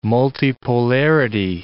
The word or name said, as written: multipolarity